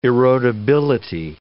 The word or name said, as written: erodibility